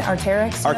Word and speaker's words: Arc'teryx: Arc'teryx? Is that how you pronounce it? Arc'teryx? – Yeah.